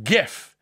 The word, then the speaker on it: GIF: Two, the good news is that the man who create invented it has said in an interview, he said it's called GIF, Hard G. GIF.